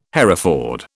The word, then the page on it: Hereford: British English